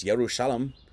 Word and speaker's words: Jerusalem: So the place named Jerusalem, pronounced Yerushalem, in Hebrew, is a combination of two words.